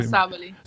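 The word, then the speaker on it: Sabally: Ja, Sabali. – Sabali, wie die Kommentatoren bei den College Games habe ich es versucht.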